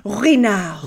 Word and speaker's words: Ruinart: And if I said it phonetically, it's Ruinart, but it's my friend who's French said it, and she said like Ruinart, like this beautiful like Ruinart.